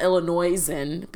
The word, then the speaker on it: Illinoisan: It's not Illinoisan because the S is silent. Illinoisan.